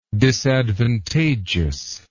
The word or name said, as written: disadvantageousness